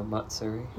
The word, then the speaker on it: Matsuri: Hanu Masuri. Did I say it right? – Hana, Sushi Hana Matsuri. – Matsuri. I always just take the T out for some reason.